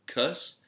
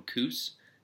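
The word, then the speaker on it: Kuss: I always say Cuss, but recently he won a stage of the Dauphiné, the Criterium du Dauphiné, and on the announcers kept calling him Coos, and I had to really question.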